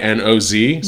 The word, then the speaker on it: Nobodinoz: But it's spelled all one word, Nobodinoz. So it's like Nobito Knows, but it's Nobody Knows.